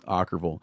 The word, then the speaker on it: Okkervil: Is that how you say it? Okkervil. – Okkervil. Yeah, huh. it's from a Russian novel.